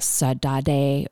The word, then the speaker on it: saudade: I think it's pronounced either like saudade or saudade.